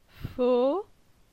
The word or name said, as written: Fo